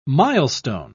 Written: milestone

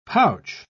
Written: pouch

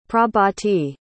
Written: prabhati

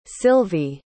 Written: Silvie

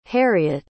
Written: harriett

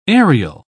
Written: aerial

